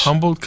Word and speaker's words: Humboldt: Humboldt Caballo.